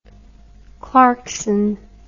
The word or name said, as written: clarkson